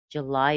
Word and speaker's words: July: July is a noun. Lie is the primary stress. You could say July. Most people would put a schwa in the first syllable.